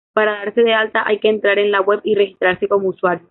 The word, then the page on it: registrarse: spanisch